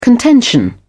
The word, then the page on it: contention: British English